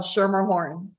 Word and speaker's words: Schermerhorn: So how do we actually pronounce your surname? As I butchered it quite badly. – No, actually you did pretty well. Schermerhorn.